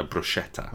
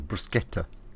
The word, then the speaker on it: bruschetta: I think it's a hard C rather than a bruschetta. – Well, bruschetta. – Bruschetta, mate.